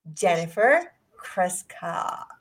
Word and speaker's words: Jennifer Kryshka: Ladies and gentlemen, let's welcome the one and only Jennifer Krishka.